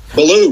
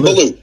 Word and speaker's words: Billou: Billou, Nicky Billou.